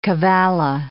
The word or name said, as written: cavalla